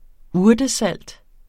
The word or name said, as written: urtesalt